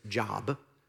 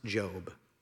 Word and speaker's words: Job: There's a book in the Old Testament, if you go back here, it's near Isaiah, and it's going to look like it says Job, but it's pronounced Job.